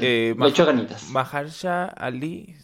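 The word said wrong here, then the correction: Mahershala Ali: Maharshala Ali. Maharshala. Me faltó, una sílaba.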